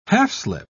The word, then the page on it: half-slip: British English